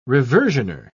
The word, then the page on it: reversioner: British English